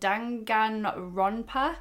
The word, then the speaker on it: Danganronpa: And just started playing this game called, I'm going to try and pronounce it, Danganronpa.